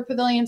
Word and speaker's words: Pavillon: And Pavilion is the French word for pavilion, so it's spelled P-A-V-I-L-L-O-N.